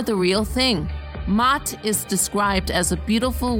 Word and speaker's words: Maat: Since the theme today is Kemetic, why not stick with it and talk about the ancient Egyptian goddess Maat? Her name is spelled M-A-A-T.